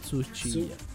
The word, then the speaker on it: Tsuchiya: ¿Lo dije bien? – Tsuchiya.